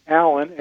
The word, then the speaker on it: Alan: Alan, A-L-A-N, with four letters. And my last name is Tillotson. T as in Tom, I-L-L-O-T as in Tom, S-O-N.